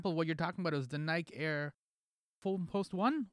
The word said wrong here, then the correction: Foamposite: Was that how you pronounce it? – The Foamposite? – Foamposite One, yeah.